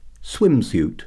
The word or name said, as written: swimsuit